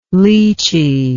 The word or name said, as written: leachy